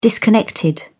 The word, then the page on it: disconnected: British English